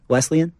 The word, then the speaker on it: Wesleyan: How do you say that town? – Wesleyan. – Wesleyan? – Yeah.